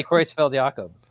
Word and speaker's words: Creutzfeldt-Jakob: Yeah, it's definitely Creutzfeldt-Jakob.